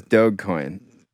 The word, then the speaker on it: Dogecoin: Hard G. – Adrian said it was the other way. – No, he said it was Dogecoin.